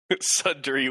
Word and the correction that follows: Suntory: Would one of you want to say how it's actually pronounced? It's Hibiki Suntory.